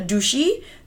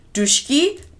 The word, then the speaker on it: Dutschke: Spelled D-U-T-S-C-H-K-E. Pronounced all type of ways depending upon who you ask. Is either Dushi, Dushki, the Tushke, eh, but we're a popular one is Dush, but we're gonna call him Dushki.